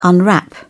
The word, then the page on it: unwrap: British English